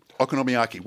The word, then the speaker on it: okonomiyaki: Okonomiyaki. Well, okonomi in... – How am I saying it right? Okonomi. Oh, no, you're right.